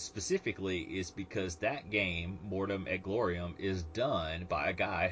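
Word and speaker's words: Mortem et Gloriam: The next thing we had was Plastic Soldier Company is doing some 15 millimeter plastics for Mortem et Glorium. Did I pronounce that right? – Sounds pretty good.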